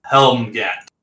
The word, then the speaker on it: Helmgant: But he responds, "I am Helmgat." – How would you spell that? – H-E-L-M-G-A-N-T.